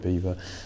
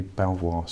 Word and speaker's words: Belvoir: So we changed the pronunciation, um, much to the distress of many people who uh, live in Lincolnshire, and we changed it to to Belvoir, so that which actually would have been the traditional um, pronunciation of the word.